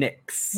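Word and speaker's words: Nyx: And we're going to start off with Carmen Petrovic and Ariana Grace versus JC Jane and Jasmine Nye. Have I said that right? – Jasmine Nix. – Nix. – Yes. – Oh, like the cosmetics.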